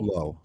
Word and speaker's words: Lowe: How does Nathaniel pronounce it? Lowe? – Lowe.